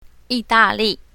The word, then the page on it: yi4da4li4: mandarin